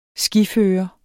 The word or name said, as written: skiføre